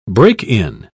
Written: break-in

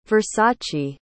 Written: versace

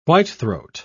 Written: whitethroat

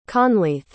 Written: conleth

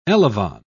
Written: elevon

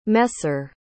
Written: messer

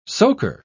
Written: soaker

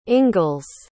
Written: ingalls

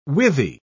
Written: withy

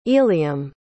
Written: eliam

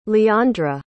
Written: leandra

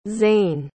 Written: zayne

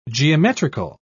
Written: geometrical

